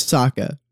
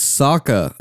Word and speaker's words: Sokka: Yes, his name is, I think his name is, his name in this show is Sokka. – Oh, it's Sokka. Okay, yeah.